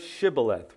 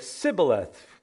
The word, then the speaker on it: Shibboleth: And if they said no, then they would say to him, "Well, then say the word Shibboleth," which means stream or flowing stream, right? And they would say Sibboleth because they couldn't pronounce it right. They couldn't say the sh. They said s.